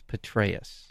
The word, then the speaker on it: Petraeus: I believe the popular pronunciation is Petraeus.